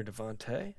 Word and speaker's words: DeVonta: Is it Devonta or Devontae? – Devontae Smith, yeah.